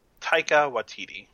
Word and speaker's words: Taika Waititi: Wa, wa, how do you pronounce that? Watiti? – Uh, Taika Waititi. – Taika Waititi, yeah.